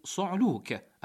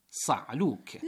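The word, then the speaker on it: صُعلوك: أيها الكرام، من الأخطاء الشائعة التي ينبغي التنويه إليها طريقة تلفظ كلمة صعلوك التي وردت بضم الصاد، في حين أن الكثيرين يلفظونها بفتح الصاد فيقولون صعلوك.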